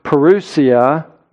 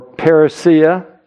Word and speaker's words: parousia: This word coming is the word parousia or parousia, as some people pronounce it, and it refers to Christ's second coming.